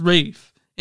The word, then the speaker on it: Ralph: One of the things that bothers me about Ray Fiennes is it's pronounced Rafe, and I'm like, why? Like they don't call it Ralph.